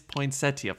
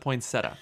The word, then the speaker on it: poinsettia: The family's poinsettia farm. I always pronounce it poinsettia, but I guess you make the I.